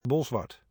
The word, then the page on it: Bolsward: ˈbɔɫsʋɑrt